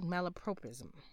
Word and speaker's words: malapropism: And um, let's hear the correct pronunciation. Malapropism. I said prop, they said prop.